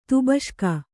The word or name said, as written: ತುಬಷ್ಕ